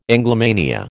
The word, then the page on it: Anglomania: American English